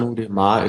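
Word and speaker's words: Arnaud Démare: Arnaud Demar ist schon relativ gut ausgesprochen.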